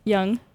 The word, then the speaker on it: Jung: Jung? – Young.